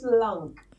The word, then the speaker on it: Long: It's Long. – So it's Long. Listen, long. Whole time I say Longkey.